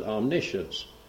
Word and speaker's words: omniscience: Number one is omniscience. Very easy to spell. It's omniscience. We pronounce it omniscience. O-M-N-I-S-C-I-E-N-C-E. Then we have omnipresence. Omni again, O-M-N-I, just P-R-E-S-E-N-C-E.